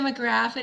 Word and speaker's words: McGraff: McGraff? I think of the crime dog. You remember McGruff, crime dog? Introduce yourself to you. – Hi, I'm Tia McGraff.